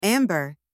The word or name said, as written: amber